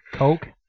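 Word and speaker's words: Koch: So We've seen recently this week, going on to a new topic, H&K, Heckler and Coke? – Yep. – See, that's so weird because it's spelled the exact same as my relatives, and they say it Cook.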